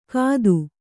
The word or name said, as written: ಕತ್ತು